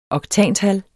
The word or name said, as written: oktantal